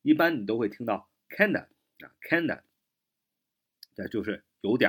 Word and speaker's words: kinda: 呃，外国人，我们所谓的 native speaker，就是说本土的这样的一个说话者呢，他常常会略读，读成 kinda 啊，kinda，kinda，kinda 啊，就不读 kind of 啊。